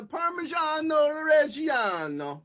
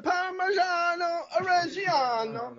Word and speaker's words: Parmigiano Reggiano: Is that how you say it? I've never known. – Yeah, it's a Parmigiano Reggiano. – And you have to do the hands, right? – Yeah, Parmigiano Reggiano.